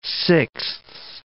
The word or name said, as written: sixths